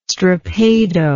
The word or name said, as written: strappado